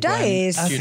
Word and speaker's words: Giudice: Okay, and sorry, we have to talk about Teresa Giudice, or however you want to pronounce her name. – Giudice. I say Giudice.